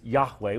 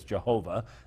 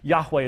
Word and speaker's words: Yahweh: In Hebrew, the name used in the Old Testament over 6,000 times is Yahweh. We slaughter that in English as Jehovah. That's not how it could have been pronounced by the Jewish people. But Yahweh is probably the best pronunciation of the divine name.